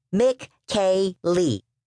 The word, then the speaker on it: Michele: His name is spelled like a girl's name, but if you say it, it's like three names in one. Mick-K-Lee. That's how they say it in Italy.